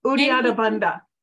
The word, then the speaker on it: Uddiyana: They have Yudhirambandaya, which I didn't say that right, but the breathing technique of yogis. – Yeah. Uddiyana.